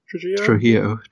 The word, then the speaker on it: Trujillo: Esther Trujillo? Trujillo? – Trujillo. Trujillo. – God damn it. – Yeah. It's like the bassist from Metallica, Rob Trujillo.